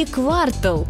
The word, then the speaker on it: квартал: Квартал или квартал? Как правильно? Большинство словарей предлагают только один вариант – квартал с ударением на последний слог.